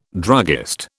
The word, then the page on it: druggist: British English